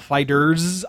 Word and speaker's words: FighterZ: There's a new fighting game based on Dragon Ball called Dragon Ball Fighter Z or Dragon Ball Fighters. I'm not quite sure how it's being pronounced. I've heard it both ways.